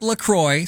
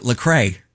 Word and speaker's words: LaCroix: It's pronounced LaCroix, so it rhymes with enjoy, but many people pronounce it LaCrae. But it's not LaCrae, it's LaCroix.